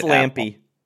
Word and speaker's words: Lampe: But there is no European NBA league in the works, but Maciej Lampe? Am I saying this right? – It's Lampe.